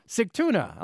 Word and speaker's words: Sigtuna: Sigtuna.